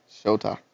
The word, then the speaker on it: Shota: Shada and Manga? Is that how you say it? – Shota.